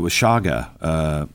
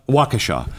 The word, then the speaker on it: Waukesha: And you know, we saw it in, what is it, Washaga? No, how do you say the name in? – Waukesha. – We saw that in Waukesha.